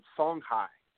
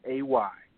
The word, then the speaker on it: Songhai: Or, and Songhai. Songhai. – Can you spell that, please, and thank you? – Yes, ma'am. It is Songhai.